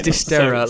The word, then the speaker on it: Dysterra: I was going to call it Dysteria, but obviously there's no. – Yeah, it's Dysteria. Like I heard it in the trailer. It is. Oh, I think okay. That is how you say it.